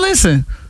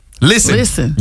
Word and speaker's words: listen: Listen is another one where the English words has a silent letter. – Silent and listen. – T. Listen. You don't hit no T in listen.